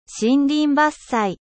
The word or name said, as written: deforestation